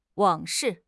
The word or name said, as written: 往事